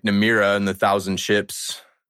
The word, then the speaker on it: Nymeria: Yeah, I'm pretty sure it's Nymeria.